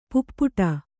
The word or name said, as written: ಪೈಪಾಡು